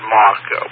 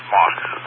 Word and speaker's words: Mach: We pronounce it Mark, Wesley. M-A-R-K. That's the way we pretty much pronounce it. So it's... Yeah, it looks like March, but it's Mark.